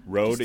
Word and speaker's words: Rode: Rode is an Australian microphone company.